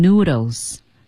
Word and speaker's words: noodles: Go back to making noodles. Noodles. 千万别读成 noodles. – 对。 – Noodles，有 L 音，昨天我们课堂上刚讲过了。Noodles，